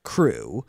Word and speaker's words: CHRU: The Carolina Harm Reduction, known as CRU, which is spelled C-H-R-U, but pronounced CRU, is a student-led program that provides life-saving harm reduction and resources to the public of Chapel Hill.